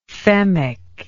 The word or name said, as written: femic